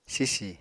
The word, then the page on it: sisi: Apatani